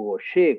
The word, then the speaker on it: Gaucher: Ustedes recordarán que hablábamos en su momento de la enfermedad de Gaucher o Goyer, como se pronuncia, el Niemann-Pick, el tay esas vías metabólicas.